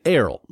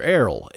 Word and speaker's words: Airl: But once again, Errol, that's how you pronounce her, Errol, A-I-R-L. Pardon me? – As far as you know, that's the way to pronounce it.